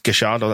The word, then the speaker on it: Gallardo: Marcelo Gallardo is what I'm going with because double L in Spanish is Y. But it turns out, because I watched I think it was a Tifo video on him, it's Gallardo. That's how they pronounce it over in Buenos Aires.